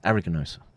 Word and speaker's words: aeruginosa: Yeah, so now we get to the next part of the stream, which is the Pseudomonas aeruginosus. Is that how you say it? Pseudomonas aeruginosus. Aeruginosa, sorry.